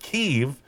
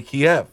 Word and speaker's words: Kyiv: Evidently, Ukrainian people pronounce it Kyiv. The Russians are the ones that say Kiev.